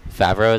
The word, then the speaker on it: Favreau: Um, it was directed by Jon Favreau.